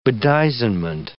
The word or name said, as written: bedizenment